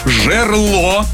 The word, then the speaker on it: жерло: Как нужно произносить? Кухонный, плодоносить, таинство, жерло. – Да-да-да. Внезапно. – Жерло и лассо.